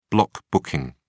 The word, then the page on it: block booking: British English